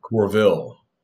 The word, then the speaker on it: Courville: Corvell.